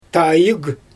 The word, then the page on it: taajɯɣ: Xyzyl